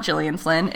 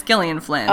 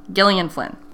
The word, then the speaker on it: Gillian: The G is pronounced hard, as in Gil, not Jill. Gillian Flynn.